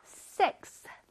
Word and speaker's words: sixth: X 다음에 TH 발음이 오기가 어렵거든. 우리 한번 해볼까요? Sixth.